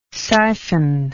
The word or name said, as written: syphon